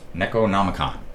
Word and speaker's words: Necronomicon: This time, we're going to go to your album, Necronomicon.